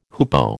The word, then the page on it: hoopoe: American English